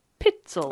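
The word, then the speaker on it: Pitezel: So it's P I T E Z E L. Peetzel. – It's like Peetzel.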